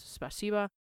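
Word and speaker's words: spasiba (спасибо): Now, I do want to say I know it ends in an O, but it is pronounced like an A, spasiba.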